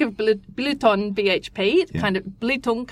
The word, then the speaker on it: Belitung: So it's pronounced Blitung. – Blitung. – But it's spelt Belitung, and often that is how it's pronounced.